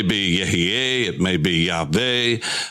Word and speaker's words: Yahweh: We call it Yahweh, Yahweh, depending upon your pronunciation. We know it's not Yahuwah, Jehovah, because we know that that is taking the vowels of Adonai, Iowah, and putting those, placing those under those four letters, and that gives you Yahuwah, Jehovah. And we know that's not the pronunciation of God's name for sure.